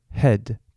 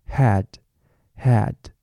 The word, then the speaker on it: head: 所以比如说像 head 头，大家会很容易念成 had had。